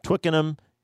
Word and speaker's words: Twickenham: He's the director of the Benedict XVI Center at St. Mary's University in Twickenham, England.